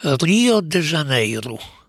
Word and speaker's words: Rio de Janeiro: I co więcej, Portugalczycy wymawiają okońcowe jak U, czyli całą nazwę tego niezwykłego miasta wymawiają Rio de Janeiro.